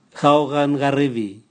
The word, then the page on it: gao gan garivi: Raga